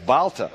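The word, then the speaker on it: Balta: I think he prefers to be his name to be pronounced Balter.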